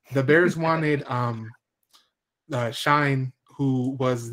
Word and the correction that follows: Schoen: I think it's Shane. – Shane, my bad. And it looks like Schoen, but yeah. Shane.